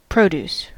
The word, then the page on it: produce: American English